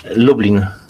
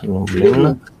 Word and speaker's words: Lublin: I'm in Poland, a city of Lublin, nearly like Dublin. – Crazy how that works. How do you say it, though? – Sorry? – How do you say it? – Lublin. – Lublin. – Lublin, yes. Lublin.